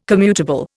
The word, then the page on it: commutable: Indian